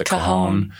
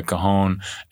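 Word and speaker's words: cajón: It's the cajon. – Yeah. – Yeah, the cajon.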